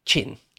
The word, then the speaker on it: Qin: Yeah, Qin is Qin, for a start. But another thing, just very quickly to say on that, is to pronounce it properly, not only do you need to know sort of what it's supposed to sound like, but you've really kind of got to put on a Chinese accent to do it real justice.